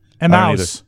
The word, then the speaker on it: Emmaus: Emas? How do you pronounce that? Emas, Pennsylvania? – Emaus. – E-M-M-A-U-S. – Mouse. Yeah. It's German. It sounds like German for mouse.